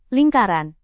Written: lingkaran

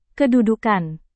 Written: kedudukan

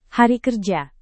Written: hari kerja

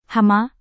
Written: hama

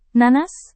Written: nanas

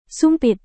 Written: sumpit

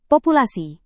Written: populasi

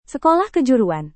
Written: sekolah kejuruan

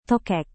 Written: tokek

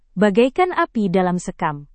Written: bagaikan api dalam sekam

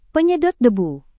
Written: penyedot debu

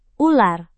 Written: ular

